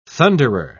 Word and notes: thunderer: British English

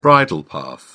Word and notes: bridle path: British English

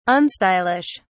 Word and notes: unstylish: British English

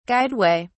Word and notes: guideway: British English

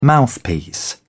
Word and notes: mouthpiece: British English

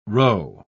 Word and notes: rho: British English

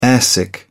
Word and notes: airsick: British English

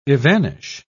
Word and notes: evanish: British English

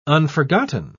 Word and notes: unforgotten: British English

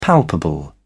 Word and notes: palpable: British English

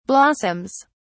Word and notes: blossoms: British English

